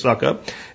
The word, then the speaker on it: Sukup: I think that's how you pronounce it. It's Suckup. S-U-K-U-P.